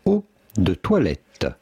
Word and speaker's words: eau de toilette: How do you go about pronouncing it? In French, we would say eau de toilette.